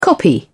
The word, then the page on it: copy: British English